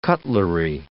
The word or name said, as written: cutlery